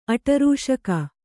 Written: ಆಟರೂಷಕ

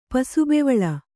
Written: ಪಸುಬೆವಳ

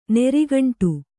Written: ನೀರ್ಗುಂಡಿ

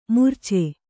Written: ಮುರಿಚು

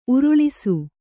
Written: ಊರಾಳಿಸು

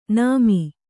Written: ನಮೆ

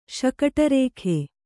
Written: ಶಕಟರೇಖೆ

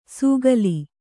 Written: ಸೂಗಲಿ

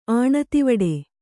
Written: ಆಣತಿವಡೆ

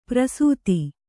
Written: ಪ್ರಸೂತಿ

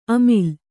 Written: ಅಮಿಲ್

